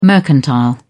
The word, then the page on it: mercantile: British English